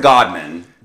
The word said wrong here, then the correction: Goodman: Goodman. Okay. Bergdorf and Goodman.